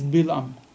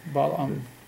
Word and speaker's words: Balaam: But the way we read it is Bilam. But I don't know why they spell it differently, Balaam.